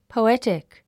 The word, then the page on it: poetic: pouétik; ポォエチィク